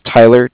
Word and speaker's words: Tyler: Yeah, it's spelled C-A-R-D-I-F-F, as in Frank Frank. – Okay. – And my name is Tyler, T-Y-L-E-R.